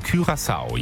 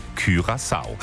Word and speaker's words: Curaçao: Spricht man übrigens wirklich so, ne? Curaçao. Jahrelang haben wir alle Blue Curaçao gesagt, ne? Dieser Cocktail. Aber laut Duden, Curaçao.